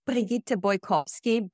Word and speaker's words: Brigitte Bojkowszky: And my name is pronounced Brigitte Bojkowszky.